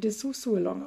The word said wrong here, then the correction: Dessous Along: aus. nee, gar nicht, Dessous Along. – Dessous Along, du hast es, du hast es drauf.